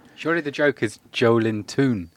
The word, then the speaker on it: Joelinton: Joelinton. – Joelinton. – Yeah, the emphasis is on the L.